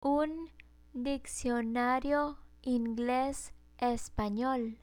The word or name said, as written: un diccionario ingles espanol